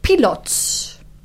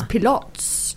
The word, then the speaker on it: Pilates: Well, did you know that the originator of Pilates was actually Josef Pilots? Actually, that's how you pronounce it. – Ah. – It's Pilots.